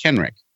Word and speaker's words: Kenric: So my name is Ken and Rick together, right? It's just Kenrick.